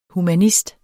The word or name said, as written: humanist